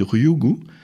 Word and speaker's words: Ryugu: Alors on est toujours dans l'actu avec vous Michel bien sûr, et on a découvert les briques de l'ADN sur l'astéroïde Ryugu, je ne sais pas si je prononce Ryugu bien. Parfait, bon accent, merci Michel.